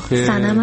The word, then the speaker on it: سنم: سنم هستم از میلان. – خیلی متشکرم سمن جان. سنم جان، سمن چیه؟ سنم درست تلفظ کن اسم مخاطب رو، خجالت هم نمیکشه به خودم.